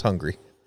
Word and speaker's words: Hungary: Hungary? – Yes. I got it. – I thought it pronounced Hungary. – It's pronounced hungry.